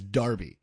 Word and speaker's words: Derby: Do you pronounce it Derby or Darby? – Derby. – With an E or an A? – Yeah, with an E. – You pronounce Derby with an E as Darby with an A.